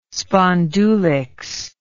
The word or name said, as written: spondulicks